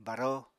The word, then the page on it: baró: Apatani